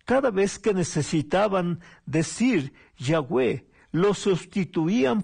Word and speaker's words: Yahweh: Cuando se agregan las vocales, se pronuncia Yahweh, que en español sería Jehová. Es la traducción que se le da al español del nombre Yahvé.